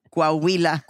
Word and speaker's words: Coahuila: Coahuila.